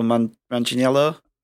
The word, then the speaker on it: Manganiello: Is it Joe Manganiello? – Yeah.